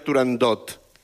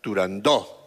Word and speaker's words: Turandot: No se debe pronunciar Turandot. – No, Turandó. – La ópera se titula Turandó.